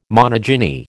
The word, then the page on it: monogyny: American English